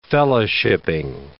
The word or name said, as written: fellowshiping